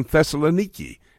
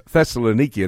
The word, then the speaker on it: Thessaloniki: And then I realized, before he started the church in Ephesus, the first church he started in Europe was the church in Thessaloniki. And by the way, that is how you pronounce Thessalonica. So many people say it. It's Thessaloniki, and that's what our Gemna, our guide, told me for sure.